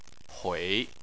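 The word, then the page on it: hui: tone 3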